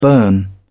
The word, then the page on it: burn: British English